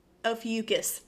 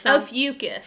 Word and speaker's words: Ophiuchus: I think it's like it sounds like mucus, okay? Ophiuchus? – Ophiuchus. That's what it was. Ophiuchus.